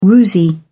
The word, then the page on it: woozy: British English